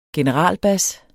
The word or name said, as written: generalbas